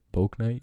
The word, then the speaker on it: Bouknight: Yeah, I know a lot of people are high on uh, that James Bonite guy. Is that how you say it? Bouknight.